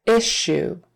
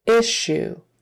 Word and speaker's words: issue: Oh, oh article, issue, issue, short I, and that SH is in the middle there. The two S's are an SH. Sh, sh, issue, issue.